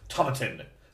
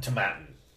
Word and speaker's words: Tomatin: Established in 1897, Tomatin, to rhyme with satin. – So it's Tomatin? – Tomatin.